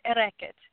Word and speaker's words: Airgead: It's Celtic. It's air record. It's actually early Gaelic, but that's the most common modern pronunciation.